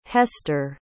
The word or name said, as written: hester